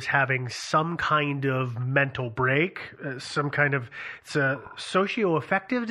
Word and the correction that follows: schizoaffective: disorder. – Schizoaffective. – Is it schizo? – Schizoaffective.